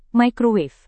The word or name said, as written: microwave